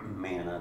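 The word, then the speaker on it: man (מן): You know, the word manna, if you look it up in Strong's, it actually is the word, and it's pronounced man. Man, M-A-N, you know. There's no A on the end of it.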